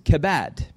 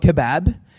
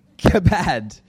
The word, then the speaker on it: kabad: the word is pronounced kabad, not kebab, but kabad.